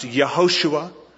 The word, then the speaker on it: Yehoshua: Of course, in Hebrew, the J isn't pronounced. Yehoshua, which means God is salvation.